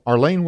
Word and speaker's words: Arlen: Arlene, welcome.